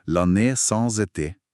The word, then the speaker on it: L'année sans été: Det förde till det som blev kallt året utan sommar i 1816 med perfekt fransk uttale L'année sans été.